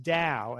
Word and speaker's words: Tao: Back in the day, there was a great restaurant in town called the Dow, T-A-O, pronounced Dow, as in Dow Jones, but the polar opposite of all things material.